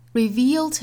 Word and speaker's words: reveal: 后面 but to reveal to him, reveal, 注意紧音的 e 再加舌侧音 eel, 大家不要读成了 reveal, 应该是 reveal. Reveal to him, his own, his 跟 own 之间的连读我们带出一个 z, zone, his own, reveal to him, his own.